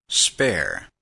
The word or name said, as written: spare